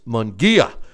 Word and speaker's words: Munguia: Did I say it right? I've been criticized about how I've been mispronouncing his name, and it's Munguia.